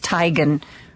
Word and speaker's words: Teigen: Her name is actually pronounced Teigen.